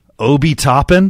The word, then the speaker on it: Obi Toppin: Obi Toppin? Did I say that right? – Yes.